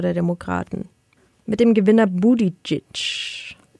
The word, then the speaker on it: Buttigieg: Pete Very Buttigieg. Close. That was close. – That was Pete Buttigieg's name. – Pete Buttigieg. Yeah. Pete Buttigieg. – Got it. – Buttigieg. Buttigieg. Buttigieg.